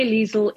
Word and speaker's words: Liezl: My, my Liezl is L I E Z L.